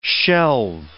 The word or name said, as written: shelve